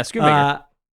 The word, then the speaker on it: Schoonmaker: Did I pronounce that right? Schoonmaker? – Yeah, Schoonmaker.